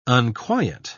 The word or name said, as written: unquiet